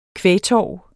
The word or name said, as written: kvægtorv